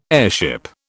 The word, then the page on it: airship: British English